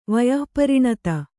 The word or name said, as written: ವಯಃಪರಿಣತ